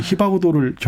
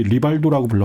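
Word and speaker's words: Rivaldo: 예전에 히바구도를 저희 리발도라고 불렀었는데 아. – 네, 뭐 R이 히읗 발음 된다고 하면서.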